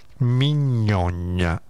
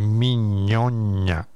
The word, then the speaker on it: Mignogna: Mignogna, come mignotta, però il finale è una GNA, quindi Mignogna, che segue un po' gli stoici, mi sembra di aver capito, non lo conosco bene.